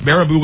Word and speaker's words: Baraboo: Baraboo, Wisconsin. – I mean, I don't know. How's it spelled? – B-A-R-B-A-O-O. Baraboo. – Baraboo. Yeah. I would say with my 12th grade education, yes, Baraboo.